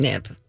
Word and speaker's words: Knip: Knip. The K is silent, of course. It's really just nip.